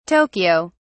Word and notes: tokyo: British English

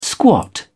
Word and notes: squat: British English

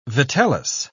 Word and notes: vitellus: British English

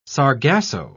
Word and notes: sargasso: British English

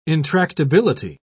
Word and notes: intractability: British English

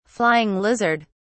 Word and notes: flying lizard: British English

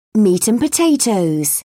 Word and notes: meat and potatoes: British English

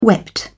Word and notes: wept: British English

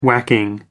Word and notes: whacking: American English

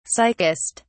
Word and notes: sickest: British English

